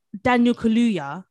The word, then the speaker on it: Daniel Kaluuya: If you saw Daniel Kaluuya, I don't know if I'm saying his name properly. – Yeah, you are.